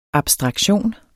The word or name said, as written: abstraktion